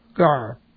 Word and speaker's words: gar: This is G, and then this is silent. You go by the vowel A, and then there's the R. Very simple verb, gar.